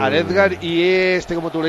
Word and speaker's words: Ié: Además con acento en la E, Ié,